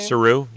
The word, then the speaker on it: Saru: Um, it's Saru, right? Is that how you say his name? – Saru, yeah, yeah.